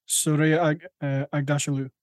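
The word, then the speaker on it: Shohreh Aghdashloo: I'm probably going to butcher her name, I'm so sorry, but it's Soraya Agdashalu. – I believe that's how her name is pronounced.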